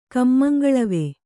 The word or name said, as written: ಕಮ್ಮಂಗಳವೆ